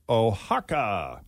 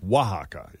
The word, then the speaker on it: Oaxaca: also ranked the best international cities, and their top pick is Oaxaca, Mexico. Oaxaca. It's pronounced Oaxaca. It's spelled O A X A C A. And according to the phonetic pronunciation here on this article, it's pronounced Oaxaca. – Oaxaca.